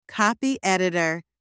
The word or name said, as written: copy-editor